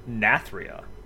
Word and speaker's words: Nathria: Congratulations, you actually got the name right. I thought it was Zero Shot. You actually said Nathria.